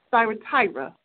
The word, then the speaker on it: Thyatira: Thyatira, okay.